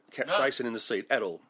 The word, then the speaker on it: capsaicin: And capsaicin, which is the am I saying that right? – Capsaicin. It's the active ingredient.